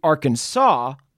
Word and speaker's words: Arkansas: Finally, in 1881, the state made Arkansas the official pronunciation, and later a law made it technically illegal, or at least discouraged, to mispronounce Arkansas in Arkansas.